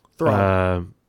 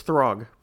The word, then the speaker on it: Throg: Throg. – And you get. – Throg. It's Throg. Pronounce it correctly.